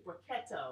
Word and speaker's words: Brachetto: The Brachetto. – The Brachetto. – Did we pronounce it right? I think it's Brachetto.